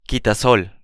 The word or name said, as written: quitasol